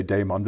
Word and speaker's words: daimon: D-A-I-M-O-N. – D-A-I-M-O-N. I like to say daimon. It might be more correct to say daemon, but basically it's your call. – Okay. – So I like to say daimon.